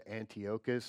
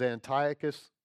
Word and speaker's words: Antiochus: And the Seleucid kingdom, the last ruler, the last king of the Seleucid kingdom was Antiochus the Fourth Epiphanes. You can pronounce it Antiochus. Some people do. That's like saying potato. But if you want to say potato, it was Antiochus the Fourth Epiphanes. And this man, this king, absolutely hated the Jews.